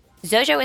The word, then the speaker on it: Xojo: Zojo is spelled like X-O-J-O.